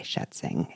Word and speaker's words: Schätzing: You loved Children of Sugarcane by Joanne Joseph, The Shining Girls by Lauren Bucus, and The Swarm by Frank Schätzing. And yes, I thought your German pronunciation was quite good.